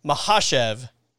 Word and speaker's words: Makhachev: And I'm glad we finally, I heard Daniel Cormier this week say the correct pronunciation is Makhachev.